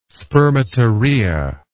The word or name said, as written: spermatorrhea